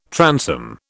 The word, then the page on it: transom: British English